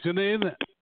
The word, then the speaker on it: Janae: Okay, Janae, uh, am I pronouncing the name correctly? – It's Janae. Imagine Renee with a J.